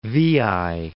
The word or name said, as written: Veii